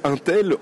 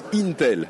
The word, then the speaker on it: Intel: toutes les deux minutes, il y a des auditeurs qui m'ont envoyé des mails pour me dire mais non, on ne dit pas Intel, on dit Intel. Donc voilà, là je l'ai bien dit. Euh, donc du côté de Intel, il y a eu des annonces de, processeurs.